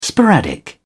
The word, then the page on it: sporadic: British English